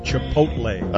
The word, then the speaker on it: Chipotle: Did I say it right, Mike? – Chipotle.